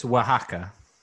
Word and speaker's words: Oaxaca: It's Oaxaca.